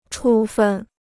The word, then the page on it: 处分: chǔ fèn